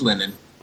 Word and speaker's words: Linan: And that would be Linan Swift? – Linan? – Linan? How do you say it? – Yeah, it's linen. – Linen? Tight. – Like laundry linen.